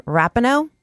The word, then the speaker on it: Rapinoe: I'm squarely straight, but Megan Rapinoe, that's how you say it, she's just flat out hot.